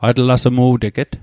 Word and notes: atlasmu'teget: Mi'gmaq

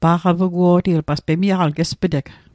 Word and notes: pa'qapuguo'ti: Mi'gmaq

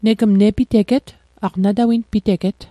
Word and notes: nepiteget: Mi'gmaq

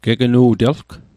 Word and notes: gegnu'telg: Mi'gmaq

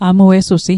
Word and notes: amuesusi: Mi'gmaq

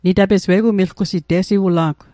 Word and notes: milgusit: Mi'gmaq

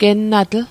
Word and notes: gennatl: Mi'gmaq

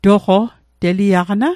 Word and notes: toqo: Mi'gmaq

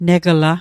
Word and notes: negla: Mi'gmaq

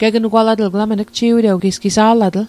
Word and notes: gegnugwalatl: Mi'gmaq

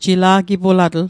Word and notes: jila'gipulatl: Mi'gmaq

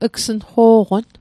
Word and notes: gsnqo'qon: Mi'gmaq

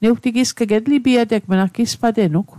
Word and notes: etlipiateg: Mi'gmaq

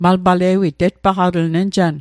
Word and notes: tetpaqatl: Mi'gmaq

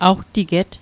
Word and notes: awtiget: Mi'gmaq

